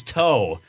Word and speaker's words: Toe: His last name is Toe, not Two. – Sorry, but it's spelled. – Like, that was gonna happen. – It's spelled T-O, man.